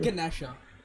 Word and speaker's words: Ganesha: No, even in India, it's Lord Ganesha.